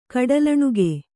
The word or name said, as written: ಕಡಲಣುಗ